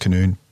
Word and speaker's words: Canon: I think it was from the Canoon trial, which is written Canon, pronounced Canoon.